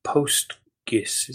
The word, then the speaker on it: PostGIS: This guy is one of the primary developers of PostGIS.